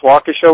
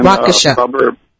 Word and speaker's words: Waukesha: Now, you are from, let's see if I say this correctly, Waukesha, Wisconsin? – Yes, Waukesha, Wisconsin. – Waukesha.